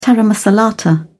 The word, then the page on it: taramasalata: British English